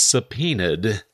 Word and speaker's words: subpoenaed: I asked, what familiar 10-letter word contains a silent B, E, and O, not necessarily in that order or consecutively? And the answer is a weird word. It's subpoenaed, S-U-B-P-O-E-N-A-E-D.